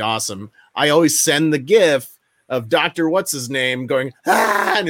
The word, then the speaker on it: GIF: One of my favorite GIFs to use, and it's pronounced GIF, when I respond to somebody and they tell me something amazing over text.